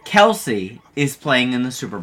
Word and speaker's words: Kelce: people would read it and say the E, but the actual name is Kelce.